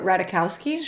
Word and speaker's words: Ratajkowski: Is that how you say her name? Yeah, Ratajkowski. – Sure. – Ratajkowski.